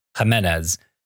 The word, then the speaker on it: Jimenez: Jimenez.